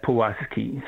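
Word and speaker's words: Pułaski: So even the spelling of the name, Pulaski, there is this W sound, but in Polish you have a letter L with a little diacritic, which stands for what we would write as a W, Pulaski.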